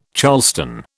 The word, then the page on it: charleston: British English